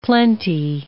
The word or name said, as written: plenty